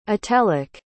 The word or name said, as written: atelic